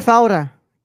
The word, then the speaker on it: Faura: Cinematography: Oscar Fura. Edited. – Oscar Faura. – Faura, thank you.